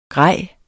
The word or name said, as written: grejer